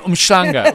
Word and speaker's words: Umhlanga: So if you are going away for VAC, if you're going to Umhlanga, darling. No, Umschlanga. Umschlanga. So, let me pronounce it correctly, Umschlanga.